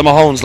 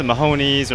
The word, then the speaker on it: Mahones: Yeah, the Mahones, like the Ramones, get it? Mahones, we're the Irish Ramones. – Yeah, because a lot of people always pronounce it Mahonies or Mahones.